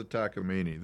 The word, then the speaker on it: Takamine: Oh, this is a Takamine.